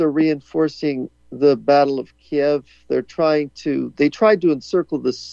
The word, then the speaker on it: Kyiv: Do you pronounce it Kyiv or Kiev when you say that, the name of the capital? – Well, it's Kiev. – Okay. – It's, you know, I watched painfully Fox News pronouncing it Kyiv, and then a Ukrainian on the other end saying Kiev, so.